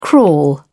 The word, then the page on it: crawl: British English